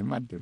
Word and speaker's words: Marte: La segunda base, le voy a dejar a usted, es Ketel Marte. Uh-huh. No veo el acento, si lo hubiera lo pronunciara. – Sí, Marte, sí,